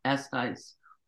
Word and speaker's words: Eshuis: My my last name is pronounced S Ice. S Ice. Okay. – Yeah, gotcha. but my publishing is Icehouse Tunes, and my reverb uh, account is Icehouse. So you, it, you didn't get it wrong. I'm just giving, giving you the Dutch pronunciation of it.